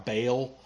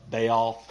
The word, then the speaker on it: Baal: you would hear about Baal uh, Baal, or depending on how you pronounce it.